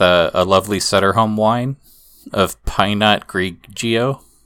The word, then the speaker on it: Pinot Grigio: I'm sorry, so it's not Pinot Grigio. It's Pinot Grigio.